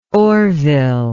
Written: orville

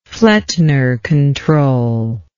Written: flettner control

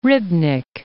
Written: Rybnik

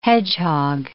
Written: hedgehog

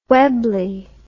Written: Webley

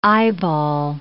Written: eyeball

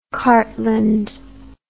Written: Cartland

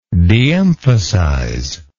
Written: deemphasize

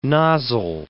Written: nozzle

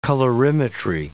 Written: colorimetry

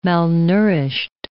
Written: malnourished

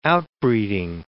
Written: outbreeding

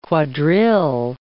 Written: quadrille

